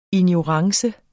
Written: ignorance

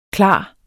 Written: klar

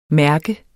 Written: mærke

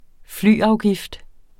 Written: flyafgift

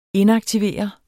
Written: inaktivere

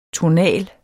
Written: tonal

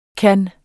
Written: kunne

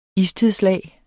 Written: istidslag

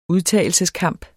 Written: udtagelseskamp

